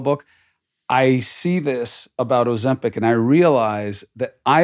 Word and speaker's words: Ozempic: Ozempic, am I saying it right? – Yeah.